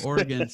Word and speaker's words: Oregon: Wait, how do you say it? Oregon? – Yeah, that's the correct way. – Oh, Oregon.